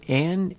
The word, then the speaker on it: Ayn: And it's called Jagged Edges, Elegant Lines, the music of Ann Inserto, featuring Sean Jones on trumpet. Ann is spelt A-Y-N, and Ann Inserto is the conductor.